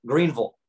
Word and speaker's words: Greenville: And then we liked Greenville, South Carolina, which I just said it wrong. It's Greenville. I'm not supposed to say Ville. Apparently that exposes that I'm not from here.